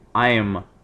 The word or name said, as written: i'm